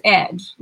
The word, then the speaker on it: Edge: Sweet is my middle name, and my last name is Edge, like the edge of a table, the edge of a knife. E-D-G-E.